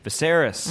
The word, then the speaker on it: Viserys: It is Viserys mm-hmm.